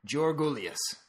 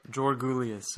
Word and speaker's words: Georgoulias: we are going to propagate the proper pronunciation, Georgoulias. Can you say it? – Georgoulias.